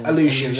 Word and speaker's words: Aleutian: The other one is Nick leading Pierce and Kate to the Aleutian Island. Am I saying that right? I've never seen the name of this place before. – Aleutian, there we go.